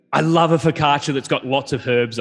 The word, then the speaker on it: focaccia: And so our first one is focaccia. Am I even saying that correctly? – Yep. – Focaccia, first thing that comes to mind.